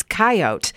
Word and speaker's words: coyote: I have always called them coyotes, but I have had people tell me, no, it's coyote. Here is the background on that. The pronunciation more favored in the West is coyote, but both are correct.